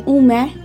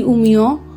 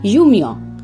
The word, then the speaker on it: Umeå: এখানে অনেক দিন ধরে রয়েছেন তারা বলেন উম্যা আমরা যারা এসেছি একটু একটু করে সুইডিশ শিখছি তারা বলি উমিও আর যারা একদম নতুন এসছেন তারা উট ইউটাকে ইউ এর মতোই উচ্চারণ করে বলেন ইউমিয়